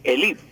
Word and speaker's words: élite: pero no con su pronunciación, sino con la con pronunciación grave. No era élite, sino élite, con acento en la I, élite.